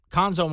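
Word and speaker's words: Cuonzo: Last year, it was Quanzo Martin. Conzo Martin, excuse me. Got to say his name right.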